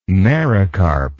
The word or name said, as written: mericarp